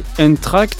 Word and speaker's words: Eendracht: Voilà, le closing c'est avec Entracte, c'est comme ça que ça se prononce et en général il est chez Onde du côté de Rennes.